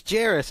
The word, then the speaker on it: Jairus: So the people on the network side approached us, the ATL folk, because we're knowledgeable, and said, how do you pronounce that? Turns out it's Wes. – It's Jairus, and this has come up on the podcast before.